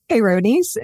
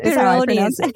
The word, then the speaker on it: Peyronie's: Peyronies. – Peyronies.